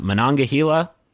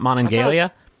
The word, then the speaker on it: Monongahela: So the game is going to take place in a fictional ski town on the eastern edge of West Virginia, in the Monongahela National Forest. By the way, did you guys know it's pronounced like that and not Monongalia? – I thought it was Monongalia.